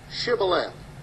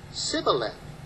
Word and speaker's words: Shibboleth: If he said nay, then said they unto him, and this is my text verse for the morning, say now, Shibboleth. And he said, Sibboleth, for he could not frame to pronounce it right.